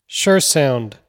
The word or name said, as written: Sjur